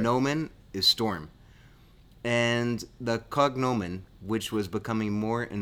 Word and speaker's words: cognomen: There was a praenomen, which was their first name, a nomen, which was the last name, and a cognomen or cognomen, or in Italian it's cognome. In Latin, it probably sounds like cognomen.